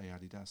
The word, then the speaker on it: Adidas: I think also if you're German, you say Adidas, so that is the correct way to say it.